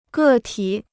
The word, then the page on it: 个体: gè tǐ